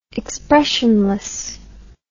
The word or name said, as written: expressionlessness